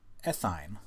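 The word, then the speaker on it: ethyne: So like ethine, which is weird to say, but that's how it's pronounced.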